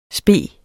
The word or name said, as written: spe